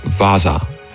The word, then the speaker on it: Vasa: And they're actually called Vasa. It's spelled with one A, but it's pronounced with two A's.